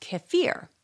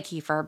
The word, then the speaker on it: kefir: Kefir, because it's also pronounced kefir. But I'll say kefir because I think that's what we here in the States say.